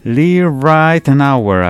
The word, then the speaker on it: Lee Ritenour: Nominado 17 veces al Grammy, Lee Ritenour, así se pronuncia.